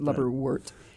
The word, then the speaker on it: lubberwort: Actually, W-R-T, most people do pronounce it like wart, but like our word, W-R-D. – Yeah. – So that's why I pronounce it lubberwort.